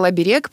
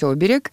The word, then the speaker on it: оберег: Ну, он сказал оберег, правильно говорить оберег.